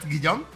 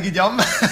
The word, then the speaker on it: Guillaume: Is that Guillaume? Yes. – Perfect. – Yes. Helene Guillaume.